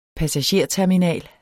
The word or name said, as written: passagerterminal